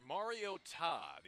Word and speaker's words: Mariota: And the announcers said, it's pronounced Mariota. The emphasis is on the last syllable.